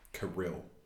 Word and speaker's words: Karylle: It's spelled Karylle. Kirill. – That's how she pronounces it.